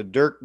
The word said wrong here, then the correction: Dierks: Bentley, is that how you say it? – Yeah, it's Dierks, D I E R K S. – Oh, there's an S.